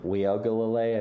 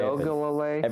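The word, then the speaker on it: Uiagalelei: So the Clemson quarterback, what's his name, like Ugalalu? – Oh, man. Weogalale, I think is how you say it. – Weogalale.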